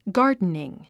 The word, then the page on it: gardening: ɡάː r dniŋ; ガーデニン